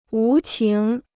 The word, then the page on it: 无情: wú qíng